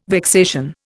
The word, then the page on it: vexation: Indian